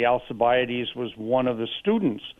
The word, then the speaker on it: Alcibiades: I probably can't say the name, but Alcibiades, is that right? – I think it's pronounced Alcibiades.